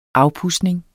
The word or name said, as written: AFPUDSNING